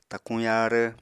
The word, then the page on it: takuñ yaarv: apatani